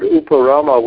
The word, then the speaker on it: uparama: So the word uparama, without a long A, the first A is not long, means sensation.